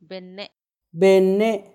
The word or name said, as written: benne